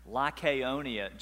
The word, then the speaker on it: Lycaonia: Even this morning, last week, I had to go over and over again, Lycaonia, just to pronounce it right, because it just doesn't come out right when you try to.